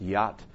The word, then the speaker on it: jot: And that business about, in the King James, it's called a jot, or it's actually pronounced yacht, a yacht or a tittle.